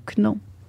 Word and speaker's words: know: T'as même pas besoin de prononcer le K. – Il est juste pourquoi ils mettent faire... un K de barre? – Juste pour faire beau. – Ben oui, mais c'est ce qu'on va. – Parce que si c'était. Ça s'écrit K-N-O-W. – Ouais. – Ça fait no. Mais s'il n'y avait pas de K, ça ferait now.